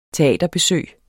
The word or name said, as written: teaterbesøg